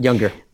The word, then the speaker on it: Junger: I am choosing uh, the film 10 Things I Hate About You, which is a directed by um, Gil Junger? Younger? I don't know how to say his last name. – Younger.